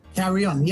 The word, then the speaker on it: Carrion: Carrion, yes.